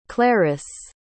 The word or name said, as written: claris